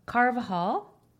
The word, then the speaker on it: Carvajal: And then on the phone, we have Suhanna Carvajal.